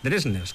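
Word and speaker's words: Dvergsnes: Lo de Dresnes, hay que explicarlo un poco, porque su apellido se escribe con deletreando D de dedo, V de Valencia, y luego Esners, ¿vale? pero al parecer en noruego, pues esa DV se pronuncia como una DRJ, Dresners, que es como él mismo lo dice, escuchado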